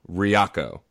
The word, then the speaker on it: Ryako: So is it Ryoko? – No, Ryako. – Ryako.